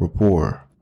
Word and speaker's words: rapport: And it looks like build rapport, but the T is silent. It's build rapport.